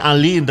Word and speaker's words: Aline: Judulnya Elaine. – Aline. – Jangan di Inggris-Inggriskan, iya. Itu namanya Aline, tetap Aline. Elaine. Jangan di London-Londokan, itu bahasa Jerman Aline bacanya.